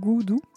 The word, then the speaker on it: Gudu: Et, euh, et, donc, elle a un label qui s'appelle Goudou, parce que c'est Peggy Gou qui fait des trucs, donc Goudou, mais ça s'écrit G-U-D-U.